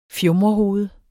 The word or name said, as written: FJUMREHOVED